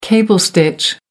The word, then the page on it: cable stitch: American English